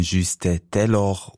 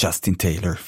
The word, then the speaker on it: Justin Taylor: Silbrig glänzende Glückseligkeit in Francis Poulencs Cembalo-Konzert Concert Champêtre gehört in dieser neuen Aufnahme mit dem französischen Cembalisten Juste Taylor und nicht Justin Taylor, wie von mir vorher fälschlicherweise auf Englisch ausgesprochen.